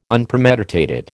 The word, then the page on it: Unpremeditated: American English